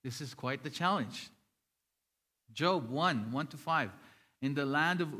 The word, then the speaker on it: Job: Then he tells us, look at Job. Who is Job? I know it reads Job, right? The first time that I was reading the Bible before, I thought it was Job. Because it is spelled Job, right?